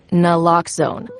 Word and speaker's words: naloxone: For the record, it's Naloxone. Nuh, like, is Naloxone addictive? Nuh-uh. Locks like Naloxone keeps humans safe like locks keep bikes safe, and own like Naloxone can save lives in our very own communities.